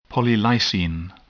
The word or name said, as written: polylysine